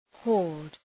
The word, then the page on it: horde: hɔ:rd